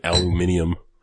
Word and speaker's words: aluminium: That's like pronouncing it aluminium. Oh, Brits. – Aluminium. I thought aluminium was Australian. Or is it British, too? – It is British, too.